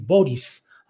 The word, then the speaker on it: Boric: En el escenario internacional, le digo que eh, pues ha tomado protesta como presidente de Chile Gabriel Boris, así lo pronuncian, se escribe Boric con C al final, de origen serbio este apellido,